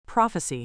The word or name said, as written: prophecy